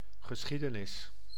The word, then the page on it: geschiedenis: [ɣə.sχiː.də.nɪs]; /ɣə.ˈsxi.də.nɪs/; /ɣə.sχi:.də.nɪs/